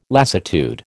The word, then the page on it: lassitude: American English